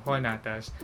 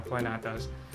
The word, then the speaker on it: Juanatas: Search mo lang ang name ko, Rome Juanatas, or mas madali siyang ipronounce ng Juanatas, Rome Juanatas, pero spelling is J-U-A, like Juan, Spanish pronunciation tayo para Juanatas, pero kung ayoko ng Spanish pronunciation, Juanatas.